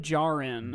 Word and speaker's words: Djarin: Djarin.